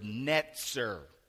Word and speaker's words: netzer: The word Nazareth comes from the Hebrew word netzer. We would spell it N-E-T-Z-E-R if you're taking notes.